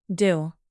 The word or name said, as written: dill